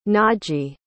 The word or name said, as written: naji